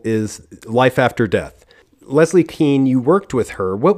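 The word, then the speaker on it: Kean: Anyway, yes, Leslie Keen, she pronounces it Keen.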